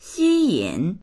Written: xi1yin3

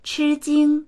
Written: chi1jing1

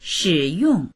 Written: shi3yong4

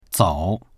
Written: zou3

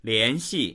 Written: lian2xi4

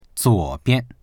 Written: zuo3bian

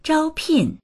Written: zhao1pin4

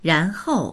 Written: ran2hou4

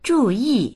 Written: zhu4yi4

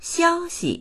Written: xiao1xi

